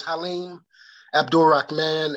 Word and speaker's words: Haleem Abdul Rahman: Yes, my name is Haleem Abdul Rahman, and I am the creator of the course, Men's Health, A Journey Towards Sensual Healing.